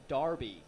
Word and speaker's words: Derby: It's spelled D-E-R-B-Y, but they all say Darby.